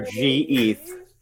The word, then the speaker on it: Geth: Do you say Geth or G-Eth? – Wow. That's not the same. That's not the same. That's one or two syllables.